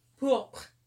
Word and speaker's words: pourpre: And then pourpre. P-O-U-R-P-R-E. – Hang on. I can't spell that quickly. Say it again. – P-O-U. – Mm-hmm. R-P-E. – P-O-U-R-P-R-E.